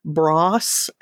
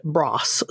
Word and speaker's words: Bros: Now, for American folk like you and I, we would pronounce it Bros, like B-R-O-S. But they always called it Bross. So I guess maybe that's how you say it in England.